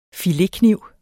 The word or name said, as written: filetkniv